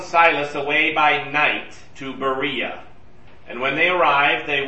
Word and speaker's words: Berea: Well, that's the Greek pronunciation. So Berea. So they go to Berea, which is a much smaller town, and it's a day's walk to the west.